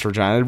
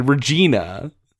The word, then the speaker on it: Regina: but they pronounce it the other way you pronounce Regina, Regina. I was like, for all you Regina Pats fans out there, I was just like, that's not how it's pronounced, god damn it. How dare you slight my city like this, the city that rhymes with fun.